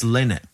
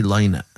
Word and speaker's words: Lynott: It's Lynott. Don't ever say Lynott. Phil Lynott, lad.